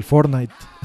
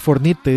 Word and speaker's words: Fortnite: así que se les complica mucho el, la pronunciación en inglés. El Fortnite, el Fornite dicen, sí, el Fornite.